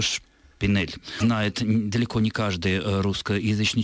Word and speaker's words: шпинель: А в слове «шпинель» ясно чувствуется «э» оборотное.